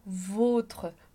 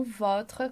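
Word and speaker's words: vôtre: E aí, o acento circunflexo que vai estar indicando que a gente tem que pronunciar votre, e não votre, como no outro caso.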